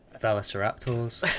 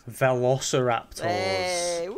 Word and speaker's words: Velociraptors: Velociraptors. – Syllable of stress off there. You were so close. – Velociraptors. Nah, it's Velociraptors.